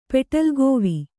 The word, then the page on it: ಪೆಟಲ್ಗೋವಿ: kannada